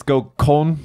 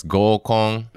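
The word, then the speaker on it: Gokon (合コン): It's Gokon, sir? – Gokon. – Don't mispronounce that word. It means something else. – You do that. Okay. you nasty man. – No, just it's Gokon. – Yes, Gokon. Okay.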